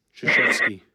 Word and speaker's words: Krzyzewski: Krzyzewski.